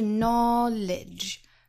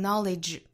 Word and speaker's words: knowledge: 我们看前半句 knowledge 这个词，它包含两音节，最后的这个止的发音，大家一定注意后面不要拖出元音，形成第三个音节了，应该是 knowledge, 哒哒，不要在止的后面拖音，读成 knowledge, 应该是 knowledge, 最后的尾音是收音，是轻的。